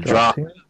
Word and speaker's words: draught: Yeah, it's like something like DRAUGHT, but I'm forgetting how to pronounce that word right now. DRAUGT. No, AUGHT. Draft. I forget how you pronounce that word right now.